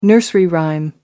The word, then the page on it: nursery rhyme: American English